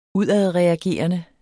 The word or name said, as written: udadreagerende